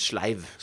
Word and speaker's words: sleiv: ustekte, slenger den inn I mikroen, som står ved siden av der, og så tar han en sånn leiv med mykstekt løk, som helt iskald. – Hva leiv? – En sleiv. – Sleiv, ja. – Bare feiluttalt.